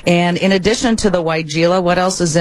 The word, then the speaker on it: weigela: Now, you mentioned weigela. I always thought it was weigela, so it's so nice to know how to say it finally. That, Sonic Bloom weigela, is part of a Proven Winners Best of Garden giveaway.